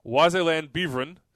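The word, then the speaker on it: Waasland-Beveren: So then he gave us the pronunciation on his club, which is, as I said, Wazeland Beveren. So, yeah.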